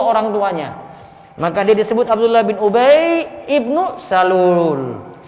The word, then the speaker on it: Abdullah bin Ubay ibnu Salul: Lalu Abdullah bin Ubay ibnu Salul. Itu cara bacanya itu Abdullah bin Ubay ibnu. Di situ hamzah wasalnya dibaca. Jadi bukan dibaca Abdullah bin Ubay bin Salul.